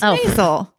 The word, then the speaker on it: Maisel: Or the Marvelous Mrs. Maisel on Amazon. – Okay, I don't say it. Marzel. – Maisel. – Maisel. – Maisel. Is it Maisel? – It's Maisel.